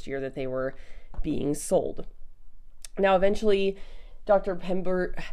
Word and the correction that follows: Pemberton: I keep mispronouncing Pemberton.